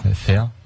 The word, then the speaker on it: Fare: F-A-R-E, tu le prononces comment? – F-A-R-E? – Ouais. – Fair. – Ben voilà, Fair City.